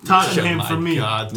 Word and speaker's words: Tottenham: The H is silent. It's Tottenham.